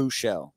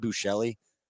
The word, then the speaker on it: Buechele: Uh, Shane so, Buechele. I, mean, I said Bouchelli on like two shows, and I couldn't.